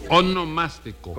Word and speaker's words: onomástico: Su monoelástico. – No, su onomástico.